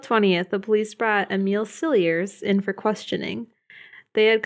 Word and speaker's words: Cilliers: It's Cilliers. – Cilliers? Okay. – Well, that's how the documentary pronounced it. Other places I saw pronounced it Cilliers, but I'm going to go with Cilliers because...